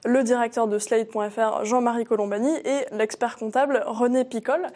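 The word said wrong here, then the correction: Ricol: Ricole, pardon.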